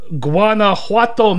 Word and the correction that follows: Guanajuato: Did I say that right? – Oh, my God. Okay. – What? – Is it spelled G-U-A-N... A, is it? – Yes. – It's Guanajuato.